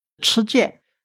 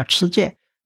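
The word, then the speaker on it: 郗鉴: 吃剑，现在有人把它念成西，这有两个音，两个音反正怎么读都对。老的读法叫吃剑。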